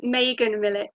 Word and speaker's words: Meagan Milic: It's Meagan Milic.